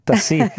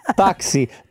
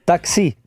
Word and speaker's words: taxi: Tassì. Non lo sa manco pronunciare. Tassì, taxi, taxi.